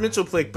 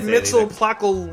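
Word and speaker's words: Mxyzptlk: Back in the day, it was pronounced Mitzelplick, but in the trailer, he pronounced it completely different. – I didn't hear how he said it. – He said Mitzelplockle, whatever.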